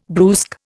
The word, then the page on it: brusque: Indian